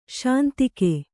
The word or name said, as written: ಶಾಂತಿಕ